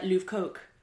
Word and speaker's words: Lovecok: It's spelled L-O-V-E-C-O-K, so it's not... – Oh, so it's actually like Louvre Coke.